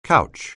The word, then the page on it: couch: anglais